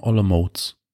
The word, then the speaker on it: Olomouc: Wird einfach nur ganz simpel ausgesprochen Olomouc. – Olomouc. – Hm. Wenn du Olomouc äh, sagen würdest, müsste da noch so ein Häkchen über dem C sein am Ende.